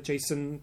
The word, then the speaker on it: Jacen: And it then became a comic book illustrated by, drawn rather, made, comicified by Jason Jackenburrows. I think it's Jason. It's spelled J-A-C-E-N. So not...